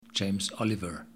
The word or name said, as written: James Oliver